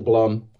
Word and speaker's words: Blum: Uh, either one. I, I, pronounce it Blum. – Okay. – Like okay. Plum.